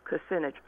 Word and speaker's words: Kucinich: She interviewed Dennis Kucinich. I probably said that wrong. – No, you didn't.